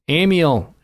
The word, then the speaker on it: Emil: A Kansas state senator recently introduced legislation to place a statue of the servant of God and heroic military chaplain, Father Emil. Am I pronouncing that correctly? – Emil.